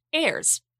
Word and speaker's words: Ayres: So I think I can take a stab in the dark and say it's pronounced Ayres.